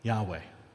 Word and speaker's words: Yahweh: It's the vowel sounds from this word matched up with the consonant sounds from this word that give us our modern pronunciation of Yahweh.